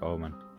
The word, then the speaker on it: Ommen: And it was 66.6 kilometres away from a town called Omen. – Fucking hell. – Which is spelled O-M-M-E-N in Netherlands. Right. In Holland. – Just like Omen.